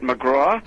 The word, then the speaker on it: McGrath: Ooh, I believe he pronounces it McGraw. – Ah, okay. – Even though it's spelled McGrath.